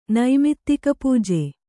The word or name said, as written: ನೈಮಿತ್ತಿಕಪೂಜೆ